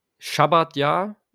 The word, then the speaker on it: Schabbatjahr: Ähm, ist, also gut, das gibt es wohl schon richtig lange, ein Schabbatjahr, also so, so wird es im Jüdischen ausgesprochen, ist gemäß der Tora ein Ruhejahr für das Ackerland in Israel.